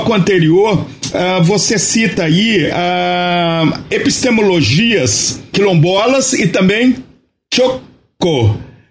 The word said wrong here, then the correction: Tchokwe: A pronúncia está correta? – Tchokwe.